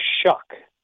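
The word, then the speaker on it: Shough: Tyler Shuck, even though that doesn't look like his last name is pronounced that way, but that's how it's pronounced.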